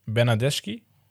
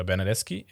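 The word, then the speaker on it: Bernardeschi: Dann bei Galatasaray im Gespräch Bernadeschi, oder Bernadeschi, eher gesagt, ausgesprochen.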